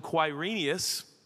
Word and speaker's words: Quirinius: This was the first registration when Quirinius, what a great name.